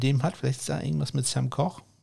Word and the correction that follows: Koch: äh, weiß man nicht. Äh, Sam Cook wird da ausgesprochen.